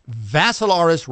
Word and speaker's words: Vassilaris: Mine spelled with my first name spelled with three I's, D-I-M-I-T-R-I. The last name is Vassilaris, rhymes with Assilaris, but you add a V to it, thus it cleans it up a little bit.